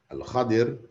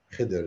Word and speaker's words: Al-Khadir: Because of that righteousness, Allah had Al-Khadir, that's actually the proper pronunciation. Khidr is what we all know. But Al-Khadir, that is the real Arabic, classical Arabic pronunciation of his name, or title which is given to him.